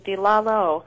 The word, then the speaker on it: Lalo: And did you say that's pronounced the Leo Concerto? I'm not familiar with that. – Oh, yes, the Lalo. Edward Lalo.